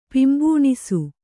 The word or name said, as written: ಪಿಂಬೂಣಿಸು